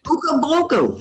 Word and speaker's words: Okanagan: Okanagan. – Good, you said it right. Yeah. Finally, I hear you say it right.